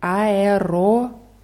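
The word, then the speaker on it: Aeroport (аэропорт): In diesem Wort gibt es zwei O's. Aeroport. Das erste O wird als A ausgesprochen und das zweite als O, weil die Betonung da drauf ist.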